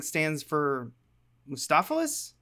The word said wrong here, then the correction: Mephistopheles: What is it? Mephistopheles? – Mm. Mephistopheles.